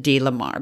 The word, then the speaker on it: DeLamar: Lamar, and that's spelled D-E-L-A-M-A-R, and that's a capital L, D. Lamar, Idaho. We're not quite sure if it's Del Lamar. – We've heard it said so many different ways. – But we're going to pronounce it D. Lamar, because in this diary also came with letters, and they always separate the D-E and then capital L-A-M-A-R. So D. Lamar, Idaho.